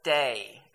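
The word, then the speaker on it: Day (δεῖ): It's only three letters. D-E-I. Delta, Epsilon, and Iota. It's three letters. Day is how you say it.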